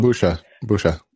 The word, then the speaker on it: Bucha: Bucha or Buka, however it's supposed to be pronounced. – Bucha.